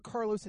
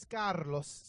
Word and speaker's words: Carlos: My name's not Carlos, it's Carlos.